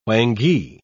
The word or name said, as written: whangee